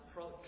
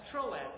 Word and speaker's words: Troas: And on the map, it's spelled Troad. I didn't do that. That was the map that I took off the internet. But apparently it can be pronounced Troad as well. But that's Troas. That's where he entered.